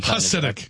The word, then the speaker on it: Hasidic: Or Hasidic, what exactly? – Hasidic. – Pronouncing it. – Hasidic.